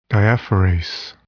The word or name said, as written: diaphorase